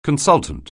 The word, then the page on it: consultant: anglais